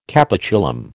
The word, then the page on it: capitulum: American English